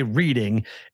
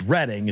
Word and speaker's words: Reading: And because people are going to say Reading, but it's Reading. It's Reading in Mass. It's Reading in Pennsylvania. But because we're idiots in the Northeast, we don't pronounce it right. So it should be Reading, but it's Reading to us. But yes. – You're right. It's spelled Reading, but it's pronounced Reading.